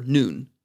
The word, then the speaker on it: nun: It comes from the root word nun, that's pronounced just like the letter nun in Hebrew, and it means to increase.